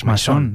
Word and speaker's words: Maison: Sí, de Maison, o de Maison, ¿cómo se dice, se diría en francés? – Maison, ¿no? Maison.